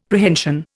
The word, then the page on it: Prehension: Indian